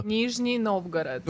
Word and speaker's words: Nizhny Novgorod: Nizhny Novgorod. – Wait, say this one again. – Nizhny Novgorod. – Nizhny Novgorod. – Yes. – Okay, tell me about Nizhny Novgorod.